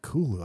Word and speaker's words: Cthulhu: I like Cthulhu. I like that. I like that pronunciation. It's supposed yeah. to be unpronounceable as oh. Lovecraft, but he said Cthulhu is the closest.